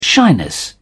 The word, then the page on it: shyness: British English